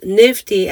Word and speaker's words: NFTE: So they pronounce themselves Nifty, and they stand for the acronym NFTE, so Network for Teaching Entrepreneurship.